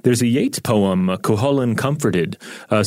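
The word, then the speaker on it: Cú Chulainn: Now, Robert, I admit before we did this episode, I had no idea who Cuchulainn was, and I probably would have pronounced it like Cuculain or whatever.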